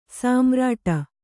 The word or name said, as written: ಸಮಾರೂಢ